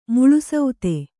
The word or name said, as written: ಮುಳುಸೌತೆ